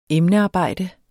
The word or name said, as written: emnearbejde